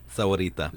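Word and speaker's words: Sahuarita: Is it improving my pronunciation? – Yes. – Sahorita. – Yep. Sahorita.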